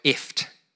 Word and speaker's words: IFTTT: So IFT is spelt I F T T T.